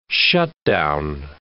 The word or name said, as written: shutdown